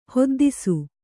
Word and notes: ಹೊದ್ದಿಸು: hoddisu; Kannada

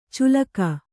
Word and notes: ಚುಲಕ: culaka

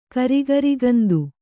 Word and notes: ಕರಿಗರಿಗಂದು: karigarigundu

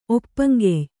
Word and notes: ಒಪ್ಪಂಗೆಯ್: Kannada

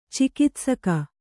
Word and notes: ಚಿಕಿತ್ಸಕ: cikitsaka

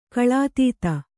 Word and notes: ಕಳಾತೀತ: Kannada

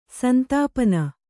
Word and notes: ಸಂತಪನ: santapana

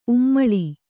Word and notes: ಉಮ್ಮುಲು: Kannada

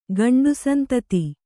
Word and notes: ಗಂಡುಸಂತತಿ: Kannada

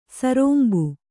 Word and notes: ಸರೋಂಬು: sarōmbu

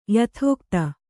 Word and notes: ಯಥಾಗತಿ: yathāgati

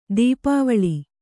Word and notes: ದಪ್ಪವಳಿ: dappavaḷi

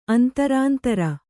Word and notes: ಅಂತರಾಂತರ: antarāntara

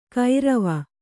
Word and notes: ಕೈರವ: Kannada

